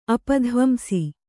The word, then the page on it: ಅಪಧ್ವಂಸ: apadhvamsa